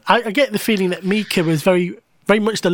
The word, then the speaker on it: Mika: Mika. It's the only way you can say his name.